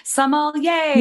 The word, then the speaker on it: sommelier: Yes, I always coach everyone just to say, like, think yay for wine. So sommelier. – Yay. – And you will always say it right.